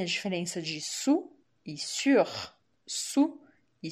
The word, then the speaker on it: sur: E se você falar sur, sur, usando o biquinho, imaginando que tem um trema nesse u, sur, sur, aí quer dizer em cima.